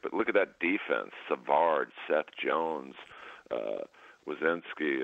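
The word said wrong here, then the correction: Werenski: or I don't know how you say it. Yeah, Wierenski.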